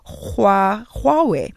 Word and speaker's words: Huawei: The H is like the Afrikaans G. I dare you to listen to the Chinese. So it's supposed to be Huawei.